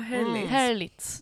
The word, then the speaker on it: Herlitz: Oh, Herlitz. – Mm. Det måste ju vara rätt uttalat.